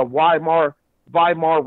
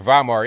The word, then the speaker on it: Weimar: The only thing people got to do is Google the Weimar Republic. It's pronounced like a V, but it's spelt with a W. That's the way Germany does it.